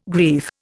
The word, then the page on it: grief: Indian